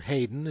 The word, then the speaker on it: Haydn: The Haydn Glee Club, or Haydn, as you would pronounce it, depending, but H-A-Y-D-N, Glee Club of this city, Utica, New York.